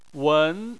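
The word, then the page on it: wen: tone 2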